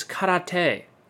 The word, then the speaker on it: karate: Or as it's pronounced in Japanese, karate, which means empty hand.